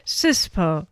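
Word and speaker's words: CSPOA: CISPO. Rhymes, almost rhymes with, of course, cesspool.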